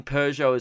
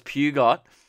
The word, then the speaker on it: Peugeot: But anyway, I was pronouncing Peugeot as Peugeot.